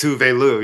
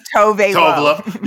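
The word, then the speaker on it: Tove Lo: Well, this weekend, been listening to Dirt Femme, the new album by Tuve Lu, which is how you pronounce it? – It's Tuve Lu, yes. – Thank you. I've been saying Tove Lu.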